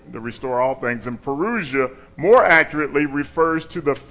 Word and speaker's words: parousia: So the word Advent in the New Testament is actually a word called parousia. Parousia. That's how it's pronounced.